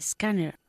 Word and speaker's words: scanner: I don't need a scanner. – I don't need a scanner. Good. La ER final en inglés se pronuncia como una A, ¿ok? Scanner.